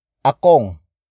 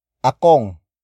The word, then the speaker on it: akong: The next word is akong. Akong. Akong. Akong is spelled as A-K-O-N-G.